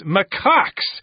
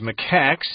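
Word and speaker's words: macaque: Let's dig into our lesson right now and talk about Japanese macaques. – Mm. – Or Japanese macaques. You can pronounce it either way.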